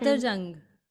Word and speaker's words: Safdarjung: I think it was Safdarjung tomb. Maybe I'm pronouncing it wrong. There was a tomb, and Safdarjung.